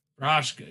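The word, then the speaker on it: Procházka: I guess if you wanted to know who the real baddest motherfucker is, would have to be Yuri Prochazka. I think that's how you say his name. His last name. – Yuri Prochazka? – Prochazka, yes. To me, that's the baddest motherfucker at the moment.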